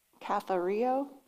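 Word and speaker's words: cathario (καθαίρω): If you want to know how to say it, phonetic spelling, cathario.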